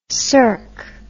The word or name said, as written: sercq